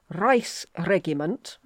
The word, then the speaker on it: Reichsregiment: He became so desperate that he allowed for the formation of a Reichsregiment, and I've got in here brackets, hard G, a council of leading princes, but on condition that they bought their position on the council from Maximilian.